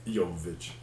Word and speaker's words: Jovovich: How do you say her name? – Jovovich. – Jovovich.